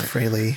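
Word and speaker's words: Frehley: Ace Fraley from the band Kiss. – Uh-huh. – It's pronounced Fraley, but everybody says Fraley.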